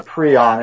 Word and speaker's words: prion: So it looks like it's spelled prion, but it's a prion.